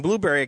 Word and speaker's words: Blubrry: Blueberry, because I got to spell it, because it's not exactly the way it sounds, is B-L-U-B-R-R-Y, podcasting.